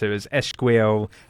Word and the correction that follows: Ezequiel: uh, Paolola. – Pa- Ezequiel. Paolola. Ezequiel. Ezequiel, Ezequiel okay. Di Paolo. Yeah.